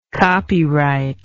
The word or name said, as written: copyrighter